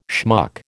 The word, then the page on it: schmuck: American English